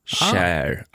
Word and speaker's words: Cher: Och då står det som i engelskans shine rimmar på bear och inte på beer. Cher, alltså.